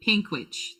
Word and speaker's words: Panguitch: I cannot pronounce this town, nor have I ever heard of it, but Panguitch and North Pinguitch. Pinguitch. Thank Pinkwich. Thank you.